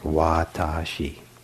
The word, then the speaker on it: watashi: So the word for I is three syllables long. It's long, like watashi.